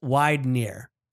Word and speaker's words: Widener: While my last name is German, pronounced Widener. So it's sort of like wide N-E-R.